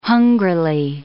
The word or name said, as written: hungrily